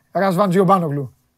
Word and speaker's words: Ρασβάν Τζιομπάνογλου: Εντάξει, ε, ε, ε, Ρασβάν, Ρασβάν Τζιομπάνογλου. Εντάξει. Εντάξει. Σωστός.